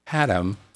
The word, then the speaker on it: Haddam: lives in Haddam, Connecticut. Pronounced the same way.